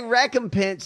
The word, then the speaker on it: recompense: And then if you're going to say recompense, say it correctly. Recompense.